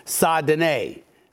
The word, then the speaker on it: Saint Denis: Saint Denis.